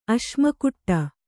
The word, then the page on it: ಅಶ್ಮಕುಟ್ಟ: Kannada